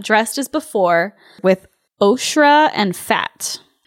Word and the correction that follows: ochre: And I think it's ochre? – Ochre. – Is how you say it?